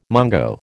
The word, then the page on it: mungo: American English